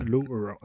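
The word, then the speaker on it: Low Roar: Alors c'est Low Horror. Par contre, je vais l'épeler parce que je, le prononce très mal. C'est L-O-W. – Oui, Low. – Voilà. Espace R-O-A-R. – Voilà. Low Horror.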